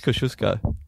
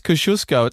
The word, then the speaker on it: Kosciuszko: Because it's Polish. It's Kosciuszko. It's not Kosciuszko.